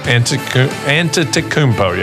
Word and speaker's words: Antetokounmpo: And how do you pronounce it? – Antetokounmpo. – Antetokounmpo, yeah.